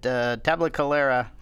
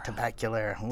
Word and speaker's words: Tabacalera: After a few months away from the cigar making, he began working with Henderson Ventura at Tabacalera. Did I say it right that time? – Nope. – Damn it! – Tabacalera.